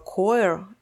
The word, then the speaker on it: choir: которое пишется C-H-O-I-R, и мне хотелось его произнести как-то типа «choir», но оказалось, что оно произносится «choir».